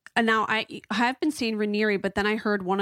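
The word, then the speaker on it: Raniere: She says it's pronounced Ranieri, like canary, but Ranieri.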